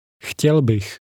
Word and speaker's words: Chtěl bych: Chtěl bych. Chtěl bych. – Now listen and repeat. – Chtěl bych. Bych. Chtěl. Chtěl bych. Chtěl bych. – Pay attention to the sound at the beginning of that.